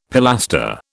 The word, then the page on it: pilaster: British English